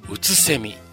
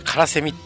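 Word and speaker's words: 空蝉: 第2 の章はね、うつせみというタイトルなんですよね。あの、空にね、セミと書いてね、うつせみなんですよね。間違ってね、僕ね、カラセミって読んじゃいそうになっちゃって、あれちょっと待てよと思ってね、さっき確認してよかったです。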